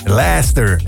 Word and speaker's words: Leicester: In Leicester, zeg ik dat goed? – In Leicester, inderdaad. – Leicester.